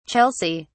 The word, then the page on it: chelsea: British English